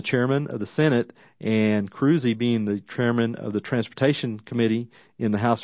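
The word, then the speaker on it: Krusee: Mike Cruzy, that's Cruzy spelled with a K, K-R-U-S-E-E.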